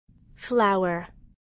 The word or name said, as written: flower